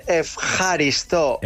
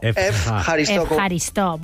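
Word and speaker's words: Efjaristo (ευχαριστώ): ¿Cómo se dice gracias? – Esta es la difícil. – A ver. – Se dice Efjaristo. – Efjaristo. Bueno, muy bien.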